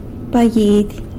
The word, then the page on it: baguidy: sanlucasquiavini